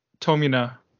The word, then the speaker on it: Tomine: And is it pronounced Tomine or Tomine? I always thought it was Tomine. I want to get that right. – Yeah, that's a good question. I think it's Tomina.